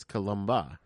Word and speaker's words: Columba: See, I thought her name, like, honestly, I thought it was going to be Columbe. – Okay. Yeah, I could see that. Yeah. Columbe. But it's Columba, right?